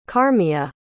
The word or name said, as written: carmia